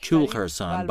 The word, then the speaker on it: Kul Khorasan: The purpose of this trip is to visit the Kul Khorasan Valley, pronounced Kul Khorasan by the locals.